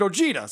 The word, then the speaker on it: Ojeda: I always pronounce it Richard Ojeda, but then Richard calls himself Richard Ojeda. So I think that's how it's pronounced, but whatever.